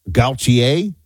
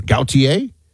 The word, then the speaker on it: Gautier: National Hurricane Center expects it to strengthen toward 100-plus miles per hour, then shear before landfall Friday evening sometime somewhere between Gautier, Mississippi. Did I say that right? It's definitely French. Is that, you know, an origin? Gautier? Like Jean-Paul, almost like Jean-Paul Gaultier, although people are going to be like, how dare you?